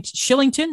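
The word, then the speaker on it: Kylington: And then Tanev Shillington, not Killington, doesn't make any sense how you pronounce it, but whatever.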